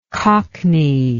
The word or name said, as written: cockneyishly